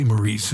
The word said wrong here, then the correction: Maurice: His first name is Morris. It's spelled Maurice, but it's pronounced Morris.